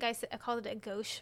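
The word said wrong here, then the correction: gose: before, but I think it's pronounced gosa.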